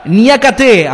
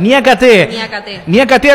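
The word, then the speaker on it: Niakaté: Sale jugando Diouf hacia atrás, descargando para Niakate, ahora sí, Inquiaté, le decía, nada que ver. Niakate al centro para que salga Gueye hacia atrás con Edouard Mendy, un arquero con pasado europeo, ¿no? – Sí, señor.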